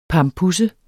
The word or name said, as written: PAMPUSSES